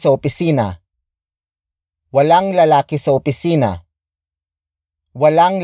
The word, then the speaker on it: opisina: Opisina is spelled as O-P-I-S-I-N-A.